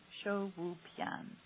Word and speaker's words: shouwupian: So Quality Life Herbs, they spell it S-H-O-U-W-U-P-I-A-N, Shu-Wu-Pian.